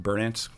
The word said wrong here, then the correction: Berdyansk: Berdyansk, and then Oshakov.